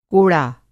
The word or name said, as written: कोड़ा